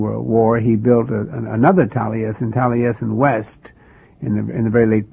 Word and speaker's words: Taliesin: Tell me about Taliesin. Am I pronouncing that correctly? – Yes, Taliesin.